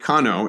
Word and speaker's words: Kano: And so the name Kano is the Japanese pronunciation of the abbreviated Chinese name for the Jia'i Agricultural and Forestry Institute. In Chinese, Jianong, Jianong in Japanese becomes Kano.